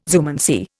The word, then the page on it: zoomancy: Indian